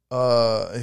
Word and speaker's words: inshallah: Say it again. – Inshallah. – Yeah, no, you said it right.